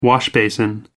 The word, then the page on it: washbasin: American English